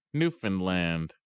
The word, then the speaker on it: Newfoundland: The trick to this is Newfoundland. – Understand Newfoundland. Ah.